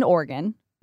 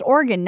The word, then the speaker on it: Oregon: So we are traveling to Portland, Oregon. Which is pronounced Oregon, not Oregon, which I didn't know.